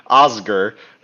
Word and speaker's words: Osgur: Oscar, who is, I just pronounced that like Oscar, like the, goodness gracious. – You did, and I hope you keep it. – Osgur, who is the orc they've captured.